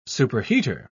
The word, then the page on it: superheater: British English